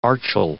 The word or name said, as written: archil